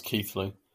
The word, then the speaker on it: Keighley: Yeah, Keighley, it's pronounced Keithley for some reason.